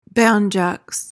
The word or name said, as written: banjax